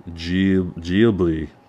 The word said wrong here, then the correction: Ghibli: I can never say it. Geobly. Can that, can either of you pronounce it correctly? Ghibli. Ghibli. Thank you.